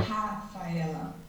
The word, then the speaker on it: Rafaela: Because my name in Portuguese, we say ha. It's like a sound. It's a very strong sound. – Yeah. – Ha, Faela.